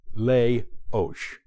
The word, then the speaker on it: Leoš: Leoš.